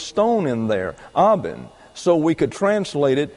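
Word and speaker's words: eben: The word for stones is aben. That's E-B-E-N, pronounced aben.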